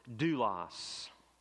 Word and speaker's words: doulos: The Greek word that is most often used to teach us this principle is a word that you and I would say that we would pronounce as doulos.